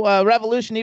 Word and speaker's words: Revolushn: But the important thing to remember is that Revolushn is spelled R-E-V-O-L-U-S-H-N. And that's how you get Revolushn.